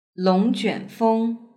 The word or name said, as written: longjuanfeng